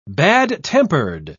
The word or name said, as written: bad-tempered